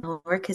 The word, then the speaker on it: Elrich: Elric is spelled ELRICH.